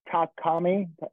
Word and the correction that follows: Murakami: Takami. Oh Jesus, Takashi Murakami. Murakami.